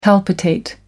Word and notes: palpitate: American English

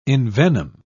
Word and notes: envenom: British English

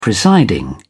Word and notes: presiding: British English

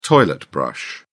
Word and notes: toilet brush: British English

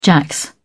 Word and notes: jacks: British English

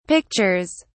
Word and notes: pictures: British English

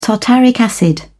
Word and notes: tartaric acid: British English

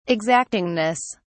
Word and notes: exactingness: British English

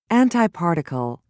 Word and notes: antiparticle: British English

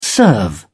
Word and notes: serve: British English

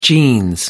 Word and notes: jeans: British English

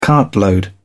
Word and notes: cartload: British English

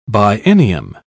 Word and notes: biennium: British English

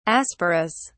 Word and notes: asporous: British English

